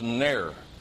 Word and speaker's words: ner: It's a word that's built off another Hebrew word pronounced ner. A ner is a lamp.